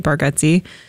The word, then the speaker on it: Bargatze: Like we've watched Nate Bargatze, but I didn't know. – It is Bargatze, even though it's spelled Bargatze, which is weird.